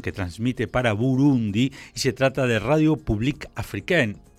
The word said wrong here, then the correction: Radio Publique Africaine: Deberías decirlo vos, que tenés una pronunciación del francés mucho más excelsa que la mía. – No, Radio Publique Africaine. – Bueno, exactamente, vos lo dijiste muy bien.